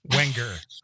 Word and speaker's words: Wenger: You just gotta chunk it out like Wang. – Okay, Wenger. So there's the G is not pronounced.